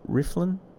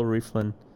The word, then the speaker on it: Rieflin: Rieflin? – Yeah. – Bill Rieflin.